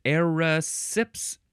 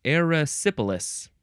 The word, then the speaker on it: erysipelas: Next is erysipelas. How do you say this? Erysipelas. Yeah, erysipelas or erysipelas. E-R-Y-S-I-P-E-L-A-S.